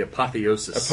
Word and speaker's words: apotheosis: Then, by the late 1980s, the church was reaching an apotheosis. What PhD in English over there? How do you say that? – I think that would be apotheosis. – Apotheosis, thank Woo.